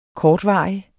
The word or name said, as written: kortvarig